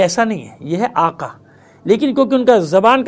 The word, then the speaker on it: آقا: جیسے آقا کو وہ لوگ کہتے ہیں آغا، یہ ہوتا ہے آقا لفظ قاف سے،